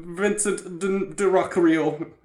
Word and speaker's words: Vincent D'Onofrio: Of course, I have with me my friend Vincent D'Onofrio.